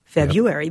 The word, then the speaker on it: February: February, yep. which is how I say it. This is the most common pronunciation in American English. The emphasis is on the first syllable.